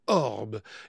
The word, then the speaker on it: Aub: Alex Hitchcock et Tom Barford, les deux saxophonistes qui s'entendent comme larrons en foire, ont décidé de monter un groupe qu'ils appellent Orb. Et ça s'écrit A-U-B. Et leur disque prend le même nom de Orb, toujours avec cette orthographe A-U-B.